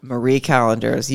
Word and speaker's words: Marie Callender's: Like burnt onions and horseradish and a Mary Callender's, like, meatloaf or something. – Oh, Marie Callender's. You put some respect on that name and say it right. It's not Mary. What? It's Marie Callender's, and she has delicious pot pies.